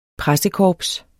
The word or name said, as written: pressekorps